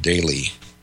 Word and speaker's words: Dailey: Daly. And for those of you that are doing a little research, his last name is spelled D-A-I-L-E-Y, Daly, Kevin Scott Daly, and I believe he was about 36 at the time that this occurred.